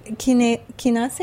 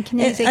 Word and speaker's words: kinase: So natto kinase, or yeah, kinase. – I think the Japanese pronounce it kinase. In English, it's actually pronounced natto kinase.